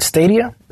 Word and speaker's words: Stadia: So Google announced that they're coming out with Stadia.